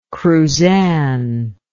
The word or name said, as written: cruzan